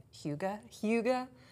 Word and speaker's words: Hygge: But really the, and I'm going to pronounce this as I have just learned this weekend, Hygge.